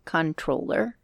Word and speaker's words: Comptroller: Actually, I just read recently it's called Controller. – Oh, really? – Yes. Yeah, that M, it's a silent P. Anyway. – Well, it's a silent M as well. – Yeah. So, yeah, it's supposed to be pronounced controller, but whatever.